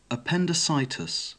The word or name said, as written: appendicitis